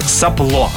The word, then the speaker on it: сопло: Далее, слово, которое, конечно, мы каждый день используем в своей жизни, да, сопло, ну, честно, наверное, так и произносим, сопло, правильное ударение.